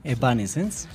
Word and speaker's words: Evanescence: Hoy nos acompaña quien nos hablará un poco de Evanescence. ¿Lo dije bien? – Evanescence, sí.